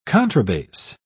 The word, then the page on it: contrabass: British English